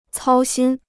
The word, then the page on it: 操心: cāo xīn